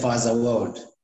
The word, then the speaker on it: Faza World: Yeah, Faza World.